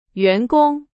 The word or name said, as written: 员工